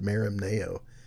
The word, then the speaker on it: merimnao: And I've heard it pronounced different ways, but I pronounce it the way it's spelled. If it was English, it'd be pronounced merimnao.